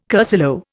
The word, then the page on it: cursillo: Indian